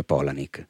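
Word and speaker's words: Palahniuk: vedi che sono sempre meno, ehm, io lì scoprì che appunto era tratto da un libro di Chuck Polanik, che si, si scrive Palaniuk, ma ho scoperto che si pronuncia Polanik.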